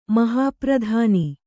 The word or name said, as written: ಮಹಾಪ್ರಧಾನಿ